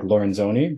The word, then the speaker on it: Lorenzoni: I would say like Lorenzoni, but I'm taking it.